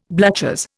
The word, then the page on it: bluchers: Indian